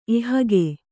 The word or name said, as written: ಎಹೆಗೆ